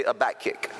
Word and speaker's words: Habakkuk: I mean, if you read the book of Habakkuk, and if you're wondering how do you say that, to say a back kick, and that's the easy way to say it, especially teenagers in here.